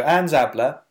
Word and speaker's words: Szabla: It's by Anne Zabler, and I know exactly how to say her name because I saw a YouTube interview with her. So, because it's spelled S-Z-A-B-L-A,